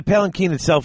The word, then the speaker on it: palanquin: And another thing that I wanted to point out, so is it palanquin? How do you pronounce palanquin. It? Yes, palanquin.